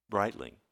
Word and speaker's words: Breitling: Breitling.